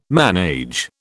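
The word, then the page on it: ménage: British English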